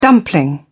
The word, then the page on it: dumpling: British English